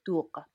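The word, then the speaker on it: doogh: Can you make the sound? It's a gentle sound at the back of your throat. Yeah. Dur. Dur. That's it. Dur.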